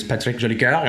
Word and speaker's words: Patrick Jolicoeur: I'm going to pronounce my name the French way today because of the guest we have. My name is Patrick Jolicoeur, and I'm honored to have Mr. Maxime Bernier on the show today.